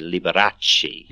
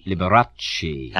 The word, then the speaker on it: Liberace: In Italian, you roll the R a little bit. You say Liberace. Liberace.